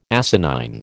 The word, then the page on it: asinine: American English